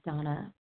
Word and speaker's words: Dana: This is Donna B, recovered compulsive overeater and bulimic, calling from Denver, Colorado. I do spell my name D-A-N-A, although it's pronounced Donna.